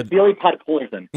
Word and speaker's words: Vasily Podkolzin: I'll say his name around Podkolzin. Is that how? Podkolzin? The player. – Vasily Podkolzin.